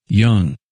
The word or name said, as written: Young